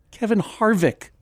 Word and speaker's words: Kevin Harvick: Kevin Harvick.